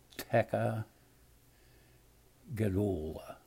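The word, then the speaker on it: tekiah gedolah: It's T-E-K-I-A-H. And then G-E-D-O-L-A-H. So it would appear to me that it probably would sound something like Teka Gedola.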